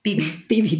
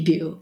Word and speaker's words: Bebe Buell: But other inspirations to Crow were Pamela DeBar and Bebe Buell. Have I said that right? – Bebe Buell. – Yeah.